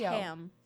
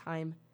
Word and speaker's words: Haim: I believe it's pronounced Haim. – Haim? Haim? I think it's Haim. H-A-I-M. All capitals. Haim.